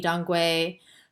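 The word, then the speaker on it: dang gui: I don't know if there's any Dongui, which is spelled Dang. Gui, and it's spelled other ways too, like it's Dong Quai, I think is another one, but I don't know if that I've seen that as common as astragalus.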